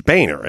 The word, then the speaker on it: Boehner: It's Boehner, isn't it? They pronounce it that way. That's how you spell it. – Yep. That's spelled like Boehner. Boner. – It's not like boner.